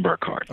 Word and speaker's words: Burkhardt: First of all, it is pronounced Kevin Burkhardt. – Okay, that's what I thought.